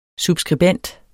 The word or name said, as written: subskribent